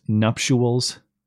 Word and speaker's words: nuptials: No, it's it looks like it's spelled N-U-P-T or it sounds like it's spelled N-U-P-T-U-A-L-S. Nuptials. But it's I-A-L-S.